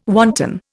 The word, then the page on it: wanton: Indian